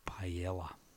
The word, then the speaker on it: paella: And then after that, after the show, you need to take your wife for a nice plate of paella.